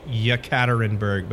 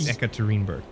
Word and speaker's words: Yekaterinburg: It says Yekaterinburg, but it's Ekaterinburg.